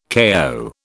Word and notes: KO: British English

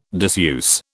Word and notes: diseuse: British English

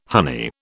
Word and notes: honey: British English

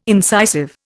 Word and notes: incisive: Indian

